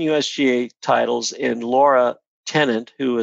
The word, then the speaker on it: Lara: It's L-A-R-A. – Yeah, but she pronounces it Laura.